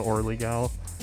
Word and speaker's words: Orly Gal: the Orly Gal? – O-R-L-Y-G-A-L.